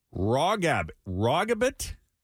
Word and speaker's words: Rawgabbit: Rawgabbit or Rawgabbit? – Spell it. – R-A-W-G-A-B-B-I-T.